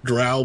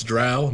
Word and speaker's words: drow: If you were going to go elf, why not go, you know, Drow Blade Singer? – Oh, jeez. – Yep. – Thank God you say it correctly. – Oh, yes, it's drow, not drow.